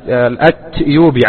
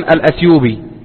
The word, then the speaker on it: الأتيوبي: ومن طرائف ما يروى أن شيخنا الشيخ العلامة محمد بن علي آدم الأتيوبي، عفوا كنت سأقول الأتيوبي، يكتب في كتاباته وفي كتبه بالتاء، بينما السائد في النطق أن الناس يقولون الأثيوبي له ولغيره.